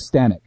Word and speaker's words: Stanek: And so, anyway, but you know, like, Frank Stanton now, you know, I know I mispronounced his last name, but Frank Stanek.